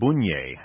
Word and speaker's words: Bunge: and Wenceslao Bunje, as his name would be pronounced in German, a member of the Bunge family.